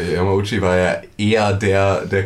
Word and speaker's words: Yamauchi: Yamauchi.